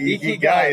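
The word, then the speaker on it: Ikigai (生きがい): Ikigai.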